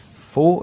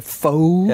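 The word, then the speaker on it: pho: Og øh, vi skal starte med en fo, eller en fo, eller en fu. Jeg tror, det er sådan meget religionsspørgsmål. Hvordan tal, udtaler du det? Jamen, jeg har jo mødt det i USA, så jeg har jo den amerikanske foe. Ja. ja, ja. It's a foe.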